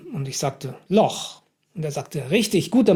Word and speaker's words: Loch: Und Loch wird in der Tat mit CH ausgesprochen.